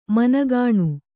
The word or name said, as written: ಮನಗುಣ